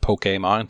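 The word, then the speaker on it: Pokémon: I'll talk about Pokemon and how it's actually pronounced Pokemon. There's an accent on the E.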